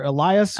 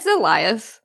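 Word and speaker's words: Alioth: Is it Eliath or Elias? – It's Eliath.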